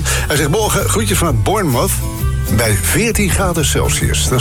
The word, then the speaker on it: Bournemouth: Je moet het volgens de Engels uitspreken als Bournemouth. Bournemouth. Een beetje inslikken.